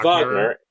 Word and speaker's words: Wagner: Is it like Richard Wagner? – It's Wagner. It's Wagner.